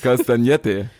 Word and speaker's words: castagnette: Castagnette muss man das aussprechen.